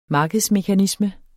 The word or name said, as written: markedsmekanisme